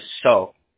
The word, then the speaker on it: Seo: So, again, my name is John, J-O-N. Last name is spelled S-E-O, pronounced as so.